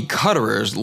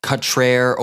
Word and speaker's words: Cutrer: I've been informed that T. Tommy Cutterer's last name is actually pronounced Cutrer or Coutrer.